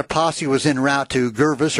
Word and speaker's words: Gervais: Is it Gervis? In Oregon, 20 miles north of Salem. – How do you spell it? – G-E-R-V-A-I-S. – Gervis.